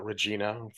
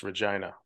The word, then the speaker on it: Regina: Now, Americans like to call it Regina for obvious reasons, but it is pronounced Regina.